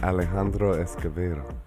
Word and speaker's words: Alejandro Escovedo: Is that how I say it? – It's close. – How do you say it? – Alejandro Escovedo. – Alejandro Escovedo. – Yeah, there you go.